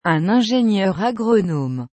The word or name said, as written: un ingenieur agronome